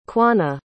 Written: quanah

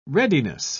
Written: readiness

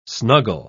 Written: snuggle

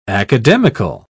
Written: academical

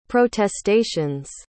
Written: protestations

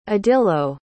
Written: adilo

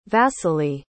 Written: vasiliy

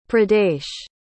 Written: pradesh

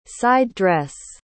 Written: side-dress

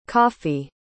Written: coffey

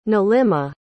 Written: nilima

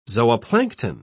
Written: zooplankton

